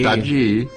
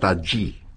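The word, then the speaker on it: Tajy: Taji. Se escribe Tají con J y griega, pero me han dicho que se pronuncia Taji.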